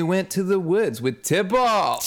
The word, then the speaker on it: Tybalt: it's pronounced Tybalt. Like he kept emphasizing the second emphasis.